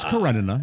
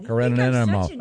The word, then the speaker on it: Karenina: Oh, no, you said it right. It's Karenina. You're right. – Thank you. – Karenina.